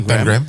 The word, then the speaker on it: Pangram: Pangram, toch? – Ja, ja. – Als je het goed wil uitspreken, ja, ja, Pangram, ja. – Pangram, dus Pangram.